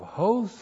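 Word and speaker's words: hos (ὡς): All right, we start out, we have host followed by hati. There's the letter H, that's the mouth breathing over the word. That's the Greek letter H. H, long O, S, hos, and there's the breathing over, so H-O-T-I, hos, hati.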